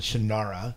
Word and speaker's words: Shannara: I always pronounce it Shannara. And since Terry Brooks is involved, I assume it's a reasonable representation of the books. Have you watched the series? If so, what do you think? And also, we noticed they pronounce Shannara like you do.